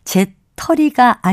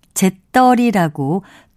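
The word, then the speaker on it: 재떨이: 이렇게 떨다와 털다를 서로 바꿔 쓸 수 있는 경우가 많기는 하지만 담뱃재를 받는 그릇을 가리키는 바른 단어는 제털이가 아니고요. 제떨이라고 된소리로 표기하고 발음합니다. 다시 말씀드리면 제떨이가 표준어고요. 제털이는 표준어가 아닙니다.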